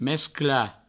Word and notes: mesclar: mescla

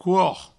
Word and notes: cor: couor

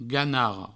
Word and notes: ganarra: ganarro

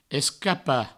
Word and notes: escapar: escapa; provençal

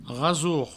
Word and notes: rasor: provençal; rasour

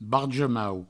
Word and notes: barja-mau: barjo-mau; Provençal

